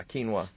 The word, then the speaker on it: quinoa: And then My Skinny Garden gave us a twit pic of, how do you say, quinoa? – Yeah, quinoa.